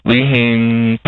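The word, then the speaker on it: LeHane: Lee Hain, pronounced like Hanes underwear, I tell people.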